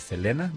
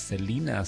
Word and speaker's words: Selena: And I know que a lot of people are saying to the correct pronunciation for the name of Selena is Selena, but us Tejanos, we know her as Selena, so we call her anything for Selena.